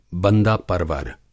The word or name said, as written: बंदा-परवर